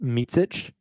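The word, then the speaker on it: Micić: Last name is pronounced Micic.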